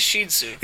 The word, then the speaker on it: Shih Tzu: The most common pronunciation is incorrect, and it's the one I use. – So it's Shih Tzu. – It is Shih Tzu. – Isn't it spelled S-H-E-E-T-Z? – No.